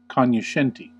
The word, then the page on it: cognoscente: American English